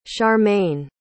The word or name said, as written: sharmaine